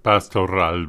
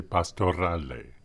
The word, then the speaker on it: Pastorale: Pastor Ral by Giuseppe Tartini. I guess I'm giving that the French and sometimes English pronunciation, but since Tartini is Italian, perhaps we should say Pastorale.